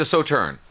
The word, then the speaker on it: Sauternes: Forgive my pronunciation. Waitrose number one, Saltiness. – Oh yeah. Saturnus. Oh, oh, it's a Sauternes.